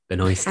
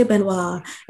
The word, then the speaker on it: Benoist: Benoist. – I always want to say Benoit, and like, I know you said that's the French pronunciation or something.